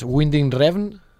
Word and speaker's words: Winding Refn: El Nicholas Winding, com es pronuncia? Nicholas? – No, és Winding Refn.